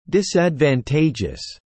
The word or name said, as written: disadvantageous